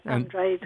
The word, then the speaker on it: Andrade: And am I saying your name correctly, or do I have the accent on the wrong syllable? – No, that's right, Andrade.